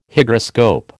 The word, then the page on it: Hygroscope: American English